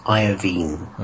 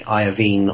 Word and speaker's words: Iovine: I think his name is pronounced Iovine.